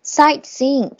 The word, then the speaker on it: sightseeing: Sightseeing，停顿一下，不读那个 t。但是如果你读了也能听懂，不读的话发音会更地道。